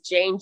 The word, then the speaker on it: Jane: My first name is Jane, J-A-N-E. My last name is spelled Z, the last letter A-R-S-E.